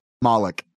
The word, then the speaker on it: Moloch: Moloch? – Moloch.